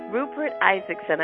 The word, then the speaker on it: Rupert Isaacson: And today we are talking with Rupert Isaacson.